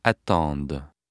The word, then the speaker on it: attendent: Ils, elles attendent. – Note that the D of the singular stem is not pronounced because a consonant follows. However, the D of the plural stem is pronounced because a vowel follows.